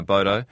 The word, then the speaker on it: Abodo: Daniel Gudsell, if you came in late, he's the founder and managing director of the company Abodo. Am I saying that right? – Yeah, that's right. – Yeah, Abodo.